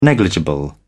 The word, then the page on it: negligible: British English